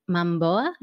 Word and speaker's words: Momoa: Okay, Jason Momoa, is that how you say it? Yeah.